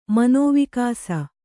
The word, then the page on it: ಮನೋವಿಕಾಸ: kannada